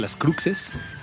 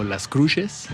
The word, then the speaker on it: Las Cruxes: Acabamos de escuchar El baile del soldado azul, el último sencillo publicado por la banda Las Cruces, pero con X, Las Cruxes, o Las Cruces, depende de en qué parte de México te encuentres, se pronunciará distinto seguramente.